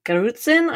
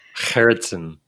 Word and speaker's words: Gerritsen: And then also contrast between data science and data analytics, which Margot Gerritsen, I always pronounce her last name wrong, but you're really good at it. – Gerritsen. – Gerritsen. – It's a lovely Dutch name, Margotje Gerritsen. – Margotje Gerritsen.